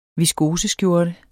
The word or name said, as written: viskoseskjorte